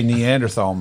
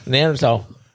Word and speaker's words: Neanderthal: That's not how you pronounce it. – Neanderthal? – Thal. Neanderthal.